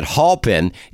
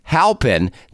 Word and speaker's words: Halpin: So, Catherine Halpin, and I was pronouncing it Halpin. It's Catherine Halpin. That's the correct pronunciation.